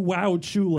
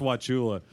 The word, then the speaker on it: Wauchula: We are going to Wauchula. – That is not how you pronounce that. – So has anyone been to Wauchula? – It's pronounced Wauchula.